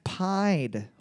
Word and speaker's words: Pied: Like pied, as in the Pied Piper.